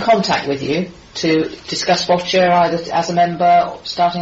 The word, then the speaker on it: Boccia: So it's Boccia as in gotcha, but it's spelled B-O-C-C-I-A, Boccia.